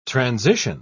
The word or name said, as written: transition